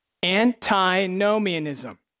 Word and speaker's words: antinomianism: Antinomianism. Yes, that is a 7-syllable word.